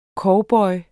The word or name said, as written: COWBOYEN